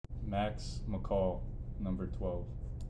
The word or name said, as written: Maxwell McCall